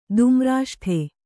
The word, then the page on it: ದುಮ್ರಾಷ್ಠೆ: Kannada